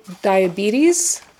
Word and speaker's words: diabetes: Oh, diabetes. Don't forget that there's that Z sound at the end.